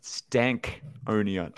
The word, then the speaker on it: Stankonia: So it's pronounced Stankonia.